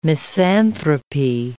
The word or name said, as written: misanthropy